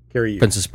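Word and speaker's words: Cary Elwes: Cary Ewes, that's how you pronounce his name? Yeah. Cary Ewes.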